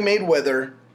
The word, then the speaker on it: Mayweather: Well, for anyways, Floyd Mayweather.